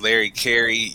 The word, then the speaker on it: Larry Carey: My name is Larry Carey. Yes, it rhymes.